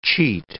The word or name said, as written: cheat